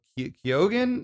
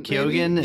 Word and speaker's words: Keoghan: You know, another thing that, how do you say his name? Barry Keoghan? – Oh, Keoghan? – Keoghan? Yeah. He is an odd dude, that actor.